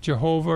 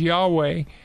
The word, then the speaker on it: YHWH: There's a number of New Testament texts where the Old Testament uses the tetragrammaton, the YHWH, sometimes pronounced Jehovah or sometimes Yahweh.